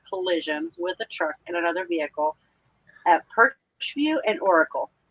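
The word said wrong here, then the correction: Pusch View: P-U-S-C-H. – V-I-E-W. – Pushview.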